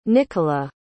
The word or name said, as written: nichola